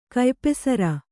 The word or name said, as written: ಕಯ್ಪೆಸರ